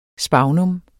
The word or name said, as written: sphagnum